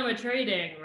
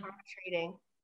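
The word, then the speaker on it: Armatrading: Oh, it's Jonah Matrading, right? – Yes. Yeah. Oh, that's how you pronounce her last name? – Mm-hmm. – Matrading.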